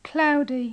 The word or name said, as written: cloudy